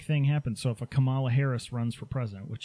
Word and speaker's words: Kamala: Kamala, that's how she pronounces it.